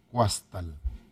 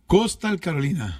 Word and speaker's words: Coastal Carolina: ¿Cómo se pronuncia ahí en inglés? Yo lo leo como está escrito. Huastal. – Coastal Carolina.